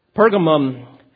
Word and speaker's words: Pergamum: Pergamum, also at times called Pergamus, it could be pronounced either way, it was about 45 miles north of Smyrna and 20 miles from the Aegean Sea.